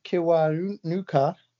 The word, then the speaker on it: Kiwanuka: Best rock album was won by The Strokes, Michael Kiwanuka, great pronunciation, or Metallica.